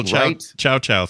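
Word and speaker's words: Chao: Oh, yeah, the little Chow things or whatever they're called. – Yeah, a little Chow. So I mean, I don't understand why. And it's spelled like Chaos, but with no S at the end.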